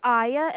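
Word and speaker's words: Aiya: It's Aya, and the last name is Smite.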